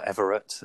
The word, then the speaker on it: Everett: So you can find me on LinkedIn and Facebook, Will Everett. And the surname is spelled E-V-E-R-E-T-T.